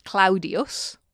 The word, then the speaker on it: Claudius: And I think the most famous incidence of this is the letter, or the letters, what we call a diphthong, two vowels being pronounced together, A-U, as in the name of the Emperor Claudius, which the correct pronunciation was Claudius. – Okay. – So A-U is ow.